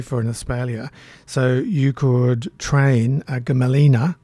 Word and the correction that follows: Gmelina: another difficult genus to pronounce. Gmelina, G-M-E-L-I-N-A, Gmelina filipensis.